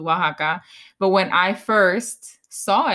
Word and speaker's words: Oaxaca: Oh, like Jen thinks it's really weird that Oaxaca is pronounced Oaxaca. Like there's an X in there that should not be Oaxaca. – It's O-X-A-C-A, right? – O-A-X-A-C-A.